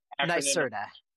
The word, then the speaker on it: NYSERDA: I know it's NYSERDA.